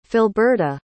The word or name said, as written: Philberta